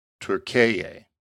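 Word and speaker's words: Türkiye: And the pronunciation goes to Turkeye.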